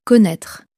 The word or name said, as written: connaitre